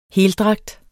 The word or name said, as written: heldragt